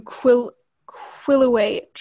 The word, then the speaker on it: Quileute: So the tribe is the Quileute tribe.